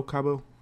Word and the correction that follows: Kubo: I always say it wrong. Kubo.